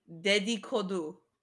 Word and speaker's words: Dedikodu: I was pretty sure that's how it was pronounced, but I didn't want to actually say it until I heard you say it, so... Because I Googled it, how to pronounce it, and that's what came up, so Dedikodu.